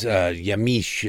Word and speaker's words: Yamiche: Of course, that is Yamiche, is apparently how you pronounce it.